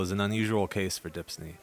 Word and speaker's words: DHPSNY: Just to recap, DHPSNY, which we pronounce Dipsney for short, stands for Documentary Heritage and Preservation Services for New York.